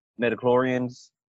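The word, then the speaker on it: midi-chlorians: I compare it to the midichlorians.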